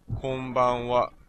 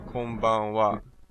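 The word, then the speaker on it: konbanwa (こんばんは): And the greeting for evening is konbanwa. Now the last ha is actually pronounced as wa here because it's the topic particle, which I'll cover in another lesson. For now, just remember it's konnichiwa and konbanwa.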